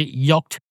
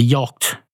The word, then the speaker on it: yacht: How do you say it? Do you say yacht? That type of boat that starts with Hawaii and ends in T. I know how to say it. I'm just not able to. A yacht. A yacht. It's a yacht. Some of those letters are silent, but I don't know which ones.